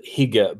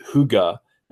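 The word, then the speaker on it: hygge: it looks like it's spelled higa, but it's pronounced huga that's mm-hmm.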